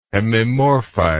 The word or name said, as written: hemimorphite